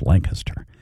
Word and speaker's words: Lancaster: This one's spelled like Lancaster, I think, without the G. Lancaster. It's Lancaster. Christina Eichard said people mispronounce the word especially by saying especially.